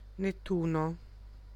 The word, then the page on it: Nettuno: /netˈtuno/; [net̪ˈt̪uː.no]